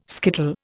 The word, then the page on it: skittle: Indian